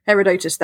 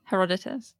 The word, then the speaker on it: Herodotus: Herodotus, that renowned, very accurate historian. – Herodotus.